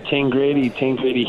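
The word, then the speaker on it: Tangradi: Obviously, was it Tingrady? Is that how you pronounce it? – Yeah, Tingrady.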